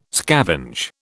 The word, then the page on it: scavenge: British English